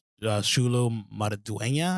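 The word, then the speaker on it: Xolo Maridueña: But Shulu Maraduena, I think.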